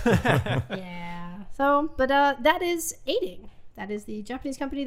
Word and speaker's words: Eighting: Aiding, yes, it's the number 8, I-N-G, and then if you look it up online, it's phonetically Aiding, E-I-G-H-T-I-N-G.